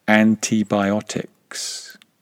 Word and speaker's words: antibiotics: Now, the word antibiotics is super interesting from a pronunciation perspective because it contains three I vowels and each of them is pronounced differently. Sorry about that. That's English pronunciation for you. Antibiotics. E-I-I. Three I vowels, each of them pronounced differently.